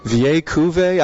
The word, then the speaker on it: Vieille Cuvée: Vieille Cuvée? I don't know how to spell it. That's what Lars taught me. That they explained to me that's how you pronounce it.